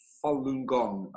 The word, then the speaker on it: Falun Gong: And, you know, Paul, for those who don't know what Falun Gong is, Falun Gong became the largest spiritual movement in China. It's a Chinese spiritual discipline in the Buddhist tradition. It's pronounced Falun Gong, and it consists of moral teachings, a meditation, four gentle exercises, and that are truly unique.